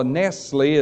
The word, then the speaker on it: Nestle: Later on, when Nessels, or Nestle as some people pronounce it,